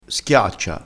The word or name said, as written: schiaccia